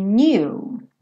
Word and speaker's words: knew: But if you only knew. Knew. Então, percebe que esse K é mudo, né? O K do no é mudo. O do passado do no também. Knew também vai ser mudo, né?